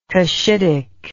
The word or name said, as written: Kushitic